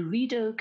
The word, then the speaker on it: Rideau: This time we were flying to Ottawa, our nation's capital, to skate the world's largest skating rink, the Rideau Canal. Gosh, I hope I said that right. – Yes, you did.